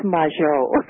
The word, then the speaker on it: Majot: I figured it was French, but I wasn't sure if that was a hard G or a soft G. – Yes. Yes, well, we've sort of used a patois and called it je. It's majeur.